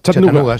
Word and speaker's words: Chattanooga: ¿Se pronuncia así en Chattanooga? – Chattanooga. Sí. Chattanooga City.